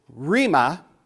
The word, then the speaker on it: rhema: The word of God generally is logos, L-O-G-O-S. But a specific word of God that directly challenges you is a R-H-E-M-A. Now, last week I pronounced this word as rhema. And a dear saint came up to me after the service and said, logos is logos, but R-H-E-M-A is, there's an A sound. It's rhema.